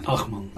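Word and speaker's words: Ajman: Är det Ayman eller Ahman? – Ahman.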